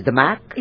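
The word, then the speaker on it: DAMAC: अब बात हुई ना, सो बेसिकली आप एक बिल्डर दमक प्रॉपर्टीज को, how do you pronounce it, दमक, it's दमक,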